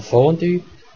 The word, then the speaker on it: Fondue: Bei Wörtern aus dem Französischen wie Fondue oder Bellevue ist die Aussprache wie im Französischen, allerdings mit Schweizer Akzent, das heißt sogar mit Betonung auf der ersten Silbe, also Fondue und Bellevue.